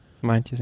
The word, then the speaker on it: Meintjes: Pierre, Pierre Latour und äh, Luis, wie spricht man das aus? Meintjes? – Meintjes, ja. Meintjes.